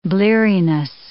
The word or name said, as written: bleariness